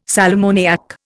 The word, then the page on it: sal ammoniac: Indian